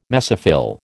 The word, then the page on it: mesophyll: American English